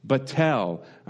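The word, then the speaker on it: batel (בטל): And it comes to us from the Hebrew word batel, batel. And that's spelt with a bet, tet, lamed, batel.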